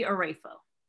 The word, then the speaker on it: Oraefo: So the phonetics people would do O-H and then Ray, like ray of sunshine and a fold. So Amy Oraefo.